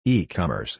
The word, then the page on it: E-commerce: English